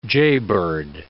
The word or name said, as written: jaybird